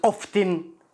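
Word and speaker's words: often: مثل كلمه اوفتن كلمه هذه اوفتن هي تنطق بالامريكي والبريطاني التي لا ينطق لكن هناك لهجات تنطق فيها التي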